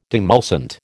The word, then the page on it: demulcent: American English